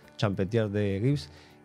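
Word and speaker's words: Champetier de Ribes: Estuve ahí en el evento de Brigitte Champetier de Gibbs, como se dice, porque su origen es francés, así que tiene esa pronunciación, Champetier de Gibbs.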